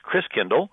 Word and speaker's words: Christkindl: Instead of the December 6th, the visit of St. Nicholas, and he says all gifts come from the Christ child, and the German pronunciation of Christ child is Christkindl, and today we pronounce that Kris Kringle, right?